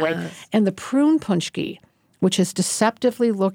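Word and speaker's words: pączki: It's spelled P-A-C-Z-K-I, but pronounced Punchki.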